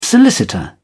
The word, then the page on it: solicitor: British English